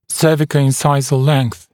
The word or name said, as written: cervicoincisal length